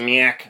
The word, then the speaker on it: Nyack: Is that how you say it? N-Y-A-C-K? Nyack.